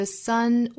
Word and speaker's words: sun: Breathing 前面是紧音，后面是松音 e a breathing, because tomorrow the sun will rise, 这个词尾部是 o 的元音，叫英音不同啊，英音是 o， tomorrow 美音是 o， tomorrow， tomorrow, because tomorrow the sun will rise. 注意 son sun 这个词的发音，其实和儿子 son 的发音是相同的。那这两个词发音都要注意元音，不要开得过大口，不是啊，不是 son，也不能发成了后鼻音，发成了 son，应该是啊，后面舌尖抵上齿龈发前鼻音，嗯，son。